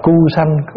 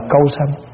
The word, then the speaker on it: Câu Sanh: Thần đó gọi là thần Câu Sanh. Có khi đọc là Cu Sanh, có khi đọc là Câu Sanh, nhưng mà thường mình đọc Câu Sanh.